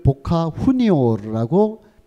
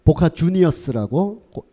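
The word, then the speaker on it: Boca Juniors: 어, 이 아르헨티나는 스페인어를 쓰기 때문에 이거를 보카 후니오라고 멋있게 읽어야 된다라는 일부 몰지각한 팬들도 있는데 아르헨티나가 스페인어를 쓰는 건 맞지만 이 팀은 원래 영국인들이 창설한 팀이라서 그 스펠링을 보카 후니오르라고 읽으면 안 되고 보카 주니어스라고 영국식 발음으로 읽는 게 아르헨티나 사람들도 타당한 거의 뭐어 광기의 현장입니다.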